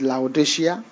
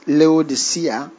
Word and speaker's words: Laodicea: So, there are many ways you can pronounce it. You can say it's Laodicea. You can say it's Laodicea, depending on where you are.